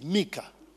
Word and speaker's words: Micah: The right pronunciation is Mika.